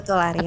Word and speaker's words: Arirang: Bener ya bacanya ya, Arirang ya? – Betul, Arirang.